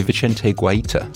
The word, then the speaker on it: Vicente Guaita: obviously, Vicente Guaita, I think is how you pronounce that, seems to be first choice at Palace now.